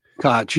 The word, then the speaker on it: Koch: On the other hand, I think there's a real sense that it is something to be celebrated, said Andy Koch, K-O-C-H, Koch. – Koch, they pronounced it Koch on the report.